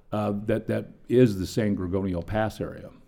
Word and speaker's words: San Gorgonio: San Gorgonio.